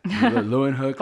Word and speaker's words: Leeuwenhoek: I think I said that right. – Leeuwenhoek. – Hopefully. – Leeuwenhoek. – Yeah.